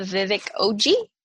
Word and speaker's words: Vivek Oji: But the book that I think might be my favorite book of the year is The Death of Vivek Oji.